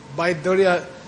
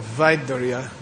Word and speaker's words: Vaiduria: Baiduria is the Tibetan mispronunciation of Vaiduria, which means lapis lazuli.